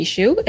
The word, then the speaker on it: Hsu: I am Vicky Hsu, and I will commend you, Cortland, for pronouncing my name correctly, even though every person with the last name H-S-U seems to pronounce it differently.